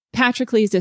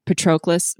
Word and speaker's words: Patroclus: Patrocles is how it's usually pronounced, but I've heard a lot of people say Patroclus, but it's Patrocles because it's paternal. So he's like, honor of the father is what his name means.